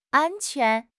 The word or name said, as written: an1 quan2